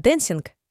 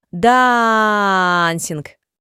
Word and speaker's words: dancing: Из Америки говорят «дэнсинг», а я говорю «дансинг».